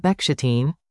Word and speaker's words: Bechstein: The Bechstein, pronounced Bechstein,